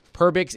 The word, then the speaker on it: Perbix: Let me spell it for all of you as you're snickering. P-E-R-B-I-X, Nick Perbix, a 6'4", right-handed defenseman.